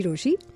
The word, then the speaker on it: Rochie: मेरा नाम है मिर्ची रोशी, नाम की स्पेलिंग है ROCHIE.